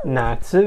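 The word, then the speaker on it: Natsu (夏): Summer is Natsu.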